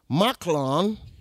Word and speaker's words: Mahlon: The name of the man was Elimelech, and his wife, Naomi, and the name of his two sons were Mahlon and Chilion. That's how that's pronounced.